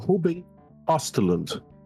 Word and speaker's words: Ruben Östlund: E como vocês já sabem, nós vamos falar de Triângulo da Tristeza, né, dirigido e roteirizado pelo senhor Ruben Ostlund.